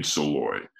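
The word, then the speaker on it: Szalay: His name is David, it's hard to pronounce, it's a Hungarian last name, but David Soloy, I think, or Soloy, David Soloy. – How do you spell it? – So that's S-Z-A-L-A-Y.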